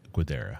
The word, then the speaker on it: Guidara: Guidara? – Will Guidara.